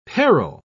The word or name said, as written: peril